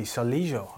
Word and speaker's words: Soligo: Soligo, I think it actually is. A silent G, Nathan.